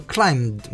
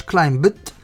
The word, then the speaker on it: climbed: كلايم CLIMB ولكن البي سايلنت لا تنطق، نقوله كلايمد ما نقولوش كلايمبت.